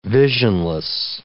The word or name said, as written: visionless